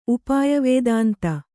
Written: ಉಪಾಯವೇದಾಂತಿ

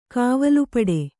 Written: ಕಾವಲುಪಡೆ

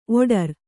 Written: ಓಡ್ರ

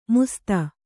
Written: ಮುಸ್ತ

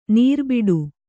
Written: ನರಿಬೇಟೆ

